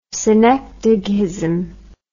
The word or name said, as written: synecdochism